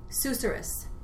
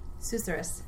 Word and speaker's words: susurrus: I wrote this word down because I thought it was so, I'd never heard of it, and I looked it up, and it's pronounced Susurrus.